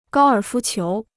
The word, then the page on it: 高尔夫球: gāo ěr fū qiú